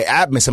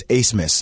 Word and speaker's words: Asmus: Like, these are the teams, like, when you find out who Max Asmus is, his name is actually spelled A-B-M-A-S. I've had so many people, hey, this guy, Abmus. I'm like, actually, it's Asmus. That's how you pronounce his name.